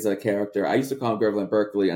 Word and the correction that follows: Berkeley: until someone corrected me. It's actually pronounced Barkley.